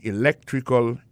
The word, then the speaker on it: electrical: I majored in electrical engineering. Electrical engineering, ça veut dire accent tombé dans electrical là, c'est sur deuxième syllabe là, electrical engineering. C'est sur deuxième syllabe là, elect là, plus appuyé.